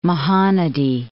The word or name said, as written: Mahanadi